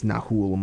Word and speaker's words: Nahuel: Yeah, I think it's Nahuel Molina is how you say his first name, but who gets the assist.